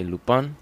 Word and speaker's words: Lupin: We actually have a another show about uh, Lupin or Lupin if you're from France. Lupin. And um, if you're feeling bougie, you want to pronounce it like a Frenchman, say Lupin.